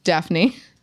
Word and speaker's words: Daphne: Just Daphne. – Daphne. Yeah, it's spelled weird though. It's Daphne. – So the PH is like your F.